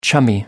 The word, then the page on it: chummy: American English